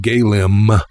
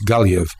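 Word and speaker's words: Gaillimh: MH is pronounced V as well, so Galway in Irish isn't Galim, it's Galiv, so forth and so on.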